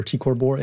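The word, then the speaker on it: T CrB: What is TCOR Bor, and am I saying that correctly? – I think so. Yep. TCOR Borealis or TCOR Bor.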